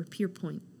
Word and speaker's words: Pierpoint: We're going to talk about Richard Pierpont, or Pierpoint Pont. I believe it's pronounced with an English pronunciation.